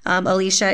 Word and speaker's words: Elicia: Alicia is spelled E-L-I-C-I-A.